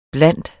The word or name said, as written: blandt